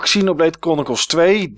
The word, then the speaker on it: Xenoblade Chronicles: Voor Xenoblade Chronicles 2, die hier niet bij zit.